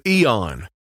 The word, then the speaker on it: aion: The word is A-I-O-N. We pronounce it eon.